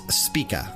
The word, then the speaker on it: Spika: They are known as Spika, I think is how you would pronounce it. S-P-I-K-A.